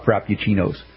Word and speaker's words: Frappuccino: But is it Frappuccino? Is that how you say it? – Frappuccino. – Frappuccino. Okay.